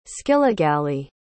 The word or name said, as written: skillagalee